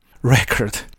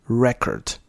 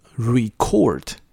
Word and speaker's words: record: record 这个字类名词的时候，就是单纯使用它当名词的时候，它是念成 record，但它同时也当做动词嘛。这个我们应该都，因为中文的翻成记录，因为你知道记录可以有动作，可以是一个名词。它如果是动作的话，它念成 record，而不念 record。